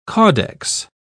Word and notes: caudex: British English

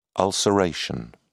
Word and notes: ulceration: British English

